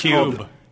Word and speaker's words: KYUB: It's Cube. – Cube. – Get it? It's spelled weird.